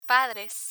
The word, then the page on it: padres: Spanish